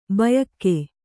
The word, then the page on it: ಭೂಯುಗ: bhūyuga